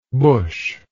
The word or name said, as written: busch